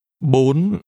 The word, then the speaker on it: Bốn: Bốn. Bốn. Bốn. – This number is pronounced with a high rising tone.